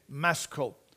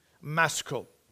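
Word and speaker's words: Maschil: And the CH is pronounced with a K, Maskil, Maskil.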